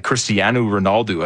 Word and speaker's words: Cristiano Ronaldo: Cristiano Ronaldo, I think, is how you technically put more of an oo sound on the end of it if you're saying it properly.